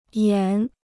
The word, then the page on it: 延: yán